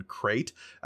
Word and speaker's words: Krayt: But it is spelled K-R-A-Y-T, as opposed to how it's, like, there is a Saltier Than Crate C-R-A-I-T.